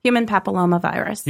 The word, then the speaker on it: human papillomavirus: Human papillomavirus.